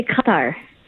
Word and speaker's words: Qatar: How is it pronounced there? – Yeah, so we say Qatar.